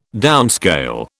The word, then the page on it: downscale: British English